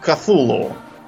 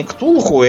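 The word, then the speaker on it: Cthulhu: Э, поэтому, например, англоязычные произносят э, это имя как- как-то как Кафулу. Вот. У нас тут утвердилось произношение Ктулху,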